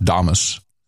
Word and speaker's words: Damus: I think it's pronounced Damus. – Not as in Nostradamus.